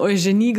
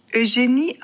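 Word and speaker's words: Eugénie: Die Königin aus eurer letzten Podcast-Folge wird tatsächlich Eugenie ausgesprochen.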